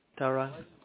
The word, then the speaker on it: Tarang: Tarang. – Can you spell that for me, please? – T-A-R-A-N-G. – T-A-R-A-N-G.